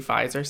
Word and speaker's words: Pfizer: Pfizer has a silent P, so it's superior.